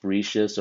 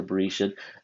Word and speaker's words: Bereshit: It's pronounced Bereshis or Bereshit. There are different ways to pronounce it.